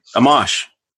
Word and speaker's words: Amash: Am I pronouncing his name correctly? I am, right? Justin Amash or Justin Amash? Is it Amash. – Tomato? – Amash. – All right, good.